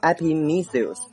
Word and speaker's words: Epimetheus: OK，那么艾皮米修斯这个名字呢，拼成 EPIMETHEUS，这个单词读成 Epimetheus， Epimetheus，艾皮米修斯的意思。